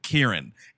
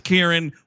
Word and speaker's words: Ciarán: And the consensus, more or less, is that because he's a dude from Northern Ireland in the north, they pronounce it Kieran. And someone thankfully shared a YouTube video of him saying his own name. Kieran. We're saying Kieran.